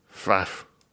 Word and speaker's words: Pfaff: P-F-A-F-F. – Mm-hmm. – I enjoy that aesthetically. I enjoy saying it. I like that there's a silent P. Mm-hmm. Silent P is my favorite silent letter.